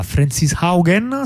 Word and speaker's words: Frances Haugen: Frances Haugen, si pronuncia così,